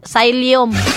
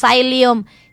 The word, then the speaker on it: psyllium: Oh, sayop di akong pag-pronounce, psyllium. So shalon po ni ako ang kuan pag-pronounce psyllium. Psyllium din ni pag-pronounce naon sa lahi man akong nasudlan nga university, oy. Kuan din ni pag-pronounce mga kaudyaka, si piyata na ako kay ang spelling PSYLLIUM. Pagbasa na psyllium.